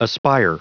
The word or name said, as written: aspire